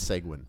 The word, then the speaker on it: Seguin: Don't say, it's Seguin. Just pronounce it correctly. – It's okay. – It's like penguin. It's Seguin.